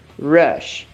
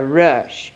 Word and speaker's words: rush: Hurry tem um som de R, porque ele começa com H. In a hurry. Mas o outro, ele começa com R. E aí ele não tem um som de R com H, H. Ele tem um R mordido, rush, in a rush, ok?